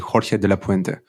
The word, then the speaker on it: Jorge de la Puente: Like, but I said it correctly, Jorge de la Puente.